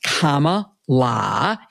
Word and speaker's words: Kamala: The question is, since President Biden dropped out of the race and Kamala, pronounced Kamala, as her little nieces told us,